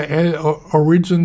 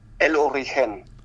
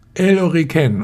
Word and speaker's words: El Origen: Also ich wünsche euch auf alle Fälle viel Glück und weiterhin viel Erfolg und für die Produkte, du hast vorhin in Spanisch ausgesprochen, ich bin hier immer bei El Origen dabei, also ich mache es mehr so in der englischen Version. Wie heißt es auf Spanisch? – El Origen.